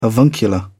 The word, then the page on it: avuncular: British English